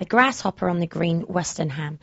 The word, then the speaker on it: Westerham: Westerham. – There we go. Thank you, Howard. Westerham.